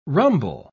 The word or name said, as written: rumble